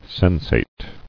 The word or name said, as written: Sensated